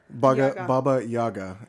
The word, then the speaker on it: Baba Yaga: Is it Baba Yaga or Baba Yaba? – Baba Yaga. And Yaga. Actually there are some, in some Slavic languages, it is pronounced Baba Yaga, like I-A-G-A.